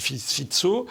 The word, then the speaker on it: Fico: La Slovaquie, il y a un type qui s'appelait Robert Fit, Alors ça s'écrit Fico, ça se dit Fitso, euh, qui euh, a été Premier ministre slovaque, avait ensuite perdu les élections, et puis là qui s'est représenté.